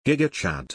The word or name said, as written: gigachad